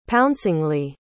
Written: pouncingly

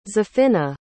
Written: zafina

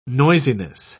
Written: noisiness

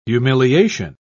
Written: humiliation

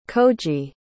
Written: koji

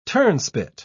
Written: turnspit

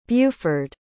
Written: buford

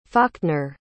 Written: falkner

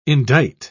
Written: indite

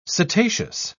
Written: setaceous

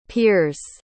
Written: peers

